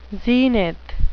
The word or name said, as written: zenith